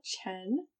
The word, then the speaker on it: Chen: Okay, so I am Kayla Chen, C-H-E-N.